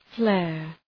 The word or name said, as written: flare